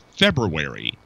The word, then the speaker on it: February: Again, it's a whole pronunciation thing, but I think it's February. There is a BR in there.